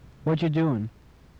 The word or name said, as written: whatcha doin